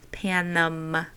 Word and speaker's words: Panem: I think it's a silent N. Panem.